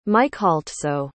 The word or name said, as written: mykhaltso